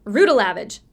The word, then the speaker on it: Rudalavage: But I'm so excited, as I said earlier, to introduce our guest for today's episode, Heather Rude. Oh no, Oh no, I'm gonna mess up the pronoun- pronunciation. I have it written out phonetically, but it's escaping me, and now these words just look like jumble. So, r- Rudalavage. – There we go. I got it.